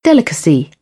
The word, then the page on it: delicacy: British English; ɪ